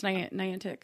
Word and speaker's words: Niantic: Okay, so I say Niantic, but other people say Niantic. – It's Niantic. – How do you say it? – Niantic. – So I'm saying it correctly.